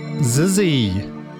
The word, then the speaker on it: Xyzzy: We also heard Future Jungle by Steph Animal from the brand new, released today, Zizzy original soundtrack, which is spelled XYZZY. I've been informed that that is how you pronounce it.